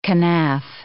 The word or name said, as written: kenaf